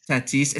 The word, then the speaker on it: Tatis: Tatis.